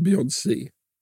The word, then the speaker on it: Beyoncé: Jag säger Beyoncé.